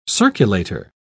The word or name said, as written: circulator